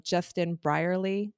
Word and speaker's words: Justin Brierley: Justin Brierley.